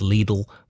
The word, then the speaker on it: Lidl: On this day in 2008, the supermarket chain, I always say Lidl, I've seen an advert where it's pronounced Lidl, but we'll say Lidl, started rationing rice in the UK.